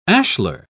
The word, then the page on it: ashlar: British English